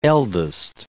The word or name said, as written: eldest